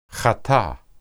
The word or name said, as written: hata